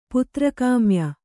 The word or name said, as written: ಪುತ್ರಕಾಮ್ಯ